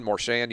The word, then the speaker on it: Marchand: Marchand, you can tell me exactly how to pronounce it. But yeah, it's Marchand.